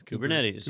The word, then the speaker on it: Kubernetes: Kubernetes? – Kubernetes.